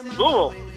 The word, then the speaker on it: Louisville: The sewers blew up, and that word you just said was actually Louisville, but we learned that the people in Louisville pronounce it how exactly? – Louisville.